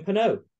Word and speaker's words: Penaud: Damien Penaud. It rhymes. It's lovely. James Lowe, Damien Penaud.